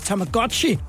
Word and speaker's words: Tamagotchi: Vi skal uh, slutte af med en, som vi jo selvfølgelig har været omkring, nemlig uh, øh, Tamaguchi, som jo i virkeligheden udtales Tamagotchi.